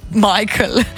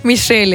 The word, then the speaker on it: Michel: Tle sem pa jaz dolg narobe izgovarjala, ker sem mu rekla, da je Michael. – Michael? – Ja, Michel je, ja, Michel.